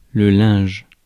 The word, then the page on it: linge: France; /lɛ̃ʒ/